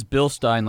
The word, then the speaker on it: Bilstein: That's actually the engineering group that I work inside of Bilstein, is technology and innovation. – And for all of you out there, it is Bilstein, like Beerstein.